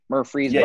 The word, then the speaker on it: Murfreesboro: Murfreesboro. Did I say it right? Murfreesboro. – Yeah, you got it right.